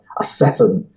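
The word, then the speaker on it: ASASSN: Yeah, it's actually named for a supernova survey, which just happened, whose acronym just happens to be ASSN, SN for supernova, and it's pronounced Assassin, which is kind of amusing, but apparently caused some consternation amongst the people who handed out comet names.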